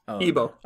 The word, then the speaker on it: Igbo: Igbo. You don't pronounce the G.